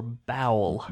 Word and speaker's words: bao: As you were saying all that, I thought, are you saying B-A-U or bowel? – Bao. B-A-O is how it's spelled, but it's pronounced bow.